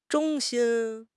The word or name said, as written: 衷心